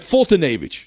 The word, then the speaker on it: Foltynewicz: Foltynewicz? Foltynewicz, is that it? Yeah, Ful- that sounds right. Foltynewicz.